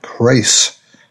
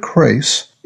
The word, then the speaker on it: Crais: Well, one of my favorite authors in this genre is Robert Crace. That's spelled C-R-A-I-S.